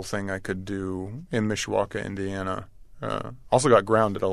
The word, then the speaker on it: Mishawaka: Mishawaka.